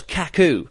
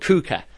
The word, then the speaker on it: Kuka: The EJC, this opening show, again, the host of the show says, it's Jay and Manu, they call themselves Kaku, but they're not called Kaku, they're called Kuka. Like, really, really basic mispronunciation, but Kaku sounds crap, and Kuka actually sounds quite cool.